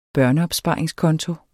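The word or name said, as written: børneopsparingskonto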